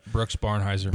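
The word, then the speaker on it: Barnhizer: And then they have like is it Barnheiser? – Yeah. – Is that how you say his name? – Yeah, Brooks Barnheiser.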